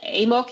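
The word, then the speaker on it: AMOC: Die Amok oder Amok, ich nutze hier häufig die englische Aussprache,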